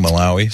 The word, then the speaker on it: Malawi: Malawi. How do you say that? – I think Malawi. – So Malawi's right. Okay.